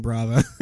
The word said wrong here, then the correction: Bava: Yeah, yeah. – And then, you know, you watch a movie once and you're like, oh shit, it's Bava.